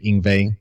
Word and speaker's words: Yngwie: It's Yngwie. Yngwie is how it's pronounced. – Y-M-G? – Yngwie.